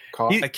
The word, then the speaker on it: Koch: Koch, but it's pronounced Koch.